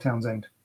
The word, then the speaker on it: Townshend: The H is silent the way, so just pronounce Plain Old Townsend. Yeah. So it is pronounced, it is spelled correctly then. So I apologize, it's spelled correctly. It is spelled Townshend, but pronounced Townsend.